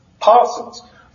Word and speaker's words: Persons: It's spelled P-E-R, like persons, but it's pronounced Parsons.